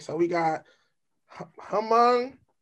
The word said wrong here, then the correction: Hmong: And no, Hmong. Hmong. – Hmong. The H is silent.